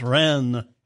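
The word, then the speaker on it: Reims: I was talking about the cathedral at Rheims. It's pronounced Rennes.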